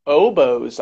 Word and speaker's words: Oboz: So they're called Oboz, I believe is how it pronounced. It's O-B-O-Z, but the Z has like a straight line over it.